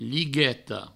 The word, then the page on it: ligueta: provençal; ligueto